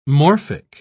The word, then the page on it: morphic: British English